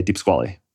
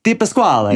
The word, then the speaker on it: DiPasquale: So uh, in America, we say Deep Squally. How do we say it? In Italian, it might be like Deep Pasquale.